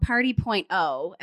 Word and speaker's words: Party.0: But you started a movement called Party.0. Am I saying that right? – Yes.